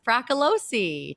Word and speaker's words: Fracalossi: Welcome to the podcast, Jess Fracalossi.